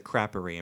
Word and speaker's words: Creperie: I think they the French pronounce it Creperie. I'm pretty sure.